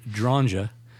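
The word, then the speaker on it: Dronge: And so Alfred Dranja, I'm going to use the Polish pronunciation since he's a Polish guy.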